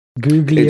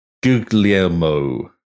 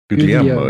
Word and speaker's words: Guglielmo: Isn't it Giulio or something? Guglio? – It's Guglielmo. Guglielmo, Italian guy, I guess, Marconi.